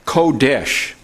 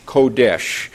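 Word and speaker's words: Kodesh (קֹדֶשׁ): Kodesh, Kodesh. And that's not the way it's spelled, but that's the way it sounds, Kodesh, K-O-D-E-S-H.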